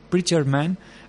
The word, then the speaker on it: Preacher Man: saw si tenéis oportunidad de, ver el videoclip de este tema, que si ya te os lo he dicho mal, el título es Preacher Man, del último trabajo de, Melody Gardot.